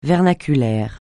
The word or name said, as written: vernaculaire